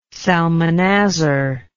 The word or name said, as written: salmanazar